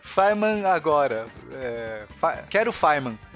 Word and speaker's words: Feynman: Atenção, Feynman se escreve Feynman, tá? Com F-E-Y-N-M-A-N, mas se pronuncia Feynman.